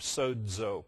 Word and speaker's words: sozo: Do you remember the verb sozo? See some of your heads going, sozo, S-O-Z-O. Pronounce as if there's a D in there, sozo.